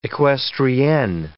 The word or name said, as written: equestrienne